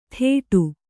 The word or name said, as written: ಥೇಟು